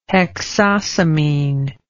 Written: hexosamine

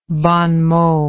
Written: bon mot

